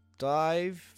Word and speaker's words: DIIV: They used to be called Dive. They're now called Dive, but it's spelled D-I-I-V.